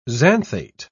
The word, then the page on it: xanthate: British English